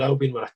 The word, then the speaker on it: Albion: Yeah, Albion.